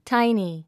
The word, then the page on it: tiny: táini; タイニィ